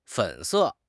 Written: fen3 se4

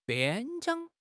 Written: 边疆

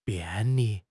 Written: 便利

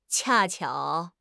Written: 恰巧

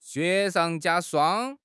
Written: 雪上加霜

